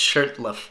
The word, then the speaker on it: Shurtleff: It's funny, I keep mispronouncing his name, but now I know it's Shurtleff, but there's a T in there.